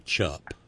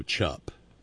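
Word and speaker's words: Mayochup: Heinz is now purveying to a credulous and unsuspecting public something that they are billing as mayo chup. – What? – Mayo chup. – Okay. What's the chub part? – Chup. Chup. C-H-U-P. Like the last syllable of ketchup.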